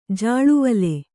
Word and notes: ಜಲ್ಲೆವಲೆ: jallevale

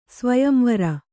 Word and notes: ಸ್ವಯಂವರ: svayam vara

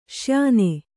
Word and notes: ಶ್ಯೇನ: śyēna

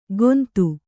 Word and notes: ಗೋಂದು: Kannada

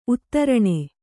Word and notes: ಉತ್ತರಣೆ: Kannada

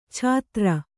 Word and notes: ಛಾತ್ರ: Kannada

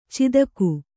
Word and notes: ಚಾತಕಿ: Kannada

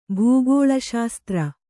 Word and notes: ಭೂಗೋಳಶಾಸ್ತ್ರ: bhū gōḷa śastra

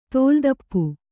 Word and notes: ತೋಲುತಪ್ಪು: tōlu tappu

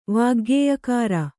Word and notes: ವಾಗ್ಗೇಯಕಾರ: kannada

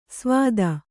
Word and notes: ಸ್ವಾದ: svāda

